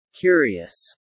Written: curious

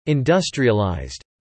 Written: industrialized

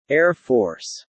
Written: Air Force

